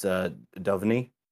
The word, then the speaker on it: Duvernay: I don't know that DeVernay's really much of a big improvement there. – Duvernay. – No, I think it's pronounced Duvernay. Duvernay.